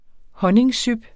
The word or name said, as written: honningsyp